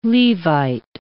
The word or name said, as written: Levite